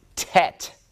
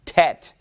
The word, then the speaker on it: tet: It is the ninth letter of the Hebrew alphabet. I just remember that it follows chet, so it goes chet, tet, chet, tet. And they rhyme with one another, so that makes it easy to remember that one.